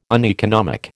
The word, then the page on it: uneconomic: American English